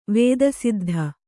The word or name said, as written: ವೇದಸಿದ್ಧ